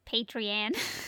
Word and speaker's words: Patreon: Yes, Patreon. That's how you say that word.